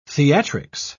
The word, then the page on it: theatrics: British English